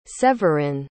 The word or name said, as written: severin